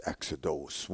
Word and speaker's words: exodos: We pronounce it exodus with a U. In Greek, it was exodos.